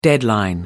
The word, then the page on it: deadline: American English